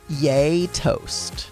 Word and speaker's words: gjetost: And I looked up how to pronounce this, and I believe it's pronounced yay toast. But it's spelled G-J-E-T-O-S-T.